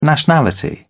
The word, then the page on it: nationality: British English